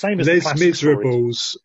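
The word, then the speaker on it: Les Misérables: And in case anyone didn't catch that, it's Les Miserables. Same as Les Miserables, if you want to kind of pronounce it properly.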